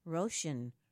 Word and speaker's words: Roshan: Get the latest and greatest news and discussion on today's ecological and climate politics on the WEFT program, The Rift, hosted by Roshan, rhymes with ocean.